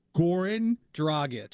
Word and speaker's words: Dragic: Grog, is it Grogic? I can't say it. Goran. Goran. Dragic. Dragic.